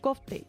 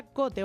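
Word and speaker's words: Kvothe: Hombre, pues va de la historia de Copte. Yo lo llamo Copte, pero se pronuncia Cote o algo así.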